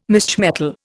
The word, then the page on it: misch metal: Indian